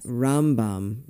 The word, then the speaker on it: Rambam: Rambam, emphasis on the first syllable when they're talking about Maimonides.